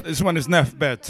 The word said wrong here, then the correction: Nesbeth: Sorry, I pronounced it incorrectly. Nesbeth. – See me ya flash it and dash it like me got it. – Nesbeth. I can't pronounce the TH properly.